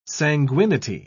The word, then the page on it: sanguinity: British English